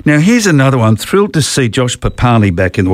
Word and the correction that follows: Papali'i: Well, hang on, there's a new pronunciation, isn't it? Josh Papali'i. Yes. We've got to change... Obviously, obviously, someone's later complained about the pronunciation, but anyway, Papali'i.